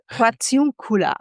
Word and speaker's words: Portiuncula: Magst du mir kurz bei der Aussprache helfen? Portiuncula? Spreche ich wahrscheinlich völlig falsch aus, oder? – Konvent Portiuncula. – Dankeschön. Ich hätte es nicht besser aussprechen können.